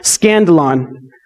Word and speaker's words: skandalon: So it's interesting because the word stumble here, if you go back to the Greek word, it's a Greek word that's pronounced skandalon.